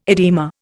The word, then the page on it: edema: Indian